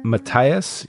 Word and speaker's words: Mattias: that Sterling Brown plays, hold on, I'll have his name here in a second, Matthias. – Well, that sounds better. – We'll get there. – We'll call him Matt. well, it is spelled M-A-T-T-I-A-S, so Matt, we're close. Matias?